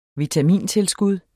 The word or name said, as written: vitamintilskud